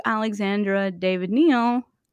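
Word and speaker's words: Alexandra David-Néel: So I think he's referring to that, and he's referring to Alexandra David Neal is a theosophist.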